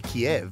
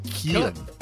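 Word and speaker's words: Kyiv: I mean, people call it Kiev, but Kiev is actually the Russian pronunciation of it. So the Ukrainians don't want it to be Kiev. So I actually, of course I did. I went online and discovered how you say it. – Yes, how? – And it's Kiev. – Kiev. – Oh, okay. – Kiev. You got to smile when you say Kiev.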